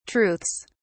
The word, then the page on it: truths: British English